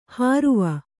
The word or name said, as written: ಹಾರುವ